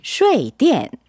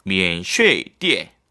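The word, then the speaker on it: 면쉐이디엔 (免税店): 1번 네. 면쉐이디엔. – 아, 꼭 기억해 주세요. 네. 면쉐이디엔. 띠엔, 네, 사성이죠.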